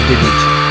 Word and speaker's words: deduce: Si dice deduce, con l'accento sulla U.